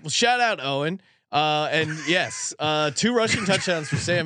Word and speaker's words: Eoin: I mean, it appears to be a word that would rhyme with groin. – Yes. So, oin. – Pulled a Eoin. Oh, he says Owen.